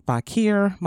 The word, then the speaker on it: Fakhir: You can find me on Facebook and Instagram, Ayana Fakhir.